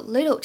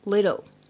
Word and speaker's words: little: 还有呢，这个 little 这个单词呢，在美音的朗读过程当中，会经常把 t 读的比较像的 little little，但是大家不要直接读成 little 啊，这样也是不对的。